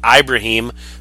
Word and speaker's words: Ibrahim: Speaking of which, um, the holes that that group was able to open up for uh, Mo Ibrahim, or I'm sorry, Ibrahim. I kept hearing it on, on Saturday that I've been saying it wrong.